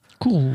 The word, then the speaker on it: Kourou: Kourou, oui.